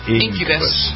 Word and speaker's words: Incubus: You pronounce it that way? Incubus or Incubus? – Incubus. Okay. Yep.